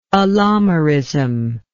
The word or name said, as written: allomerism